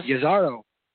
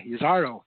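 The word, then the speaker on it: Jezero: Yazaro. You pronounce it with the Y, Yazaro. – Oh, it's not J, it's Ya, Yazaro.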